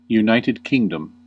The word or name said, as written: United Kingdom